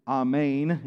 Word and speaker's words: amen: Another Hebrew word, transliterated, amen. The way it's pronounced in it supposedly is amen. If you want to hear the actual Hebrew, amen, amen. Jesus would say amen, amen.